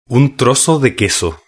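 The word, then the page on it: un trozo de queso: Spanish (Spain)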